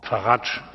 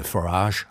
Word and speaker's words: Farage: Farage. – Mr. Farage. – President Farage. – Farage. – Nigel Farage heißt er wirklich.